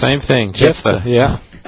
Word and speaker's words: Jephthah: Well, is that how you pronounce that name? – Yeah, Jephthah, well, at least in English.